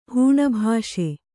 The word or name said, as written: ಹೂಣಭಾಷೆ